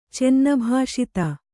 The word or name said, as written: ಚೆನ್ನಭಾಷಿತ